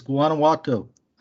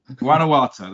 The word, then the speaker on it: Guanajuato: Prime Mining and, I always pronounce this wrong, but Guanajuato Silver. – You're making it too hard to pronounce. It's Guanajuato. – Guanajuato, that's it, yeah.